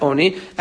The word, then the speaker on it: oni: How do you pronounce it? It's pronounced oni karin. It's pronounced oni as if it's referring to aninus, which is lashon of grief,